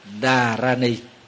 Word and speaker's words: Dharani: Thì nguyên âm tiếng Phạn đọc là Dharani. Dharani, cái chữ đó là chữ Dharani.